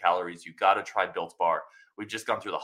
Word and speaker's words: Built Bar: I called it Bill Bar, and somebody was trying to find Bill Bars. It's Built Bar.